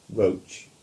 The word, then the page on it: Roche: English